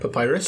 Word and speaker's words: papyrus: How do you say that? – Papyrus. – Papyrus. That's the one.